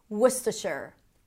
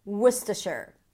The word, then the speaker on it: Worcestershire: So basically there is no vowel in the middle or a schwa if you want to be particular. Worcestershire, Worcestershire. The following search term is names.